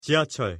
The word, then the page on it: 지하철: Korean